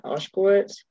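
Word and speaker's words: Ushkowitz: I had written down that, I don't know if this was like a choice by Jenna Oshkowitz. Is that how you say her name? – Yeah.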